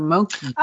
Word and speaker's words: mochi: Now, another thing that I noticed that I've never seen before is a matcha how do you pronounce this? Mochi or mochi? – Oh, yeah. Mochi.